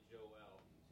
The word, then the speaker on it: Joel: So I'm just going to probably go with my traditional Joel, although in the Hebrew it's probably more accurately Joel, two syllables.